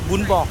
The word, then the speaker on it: bún bò: Les Français disent bò bún, mais il faut bien prononcer bún bò.